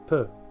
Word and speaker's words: peu: It's peu. Peu. The way you pronounce it is peu. And now that peu. Because when you pronounce it, it felt Spanish. But when you say it like this, per means less or a little bit.